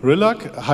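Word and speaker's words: Rillak: Ähm, Präsidentin Rillock leitet das Treffen. Rillag heißt sie, äh, ist Bajoranerin, Kardassianerin und Mensch.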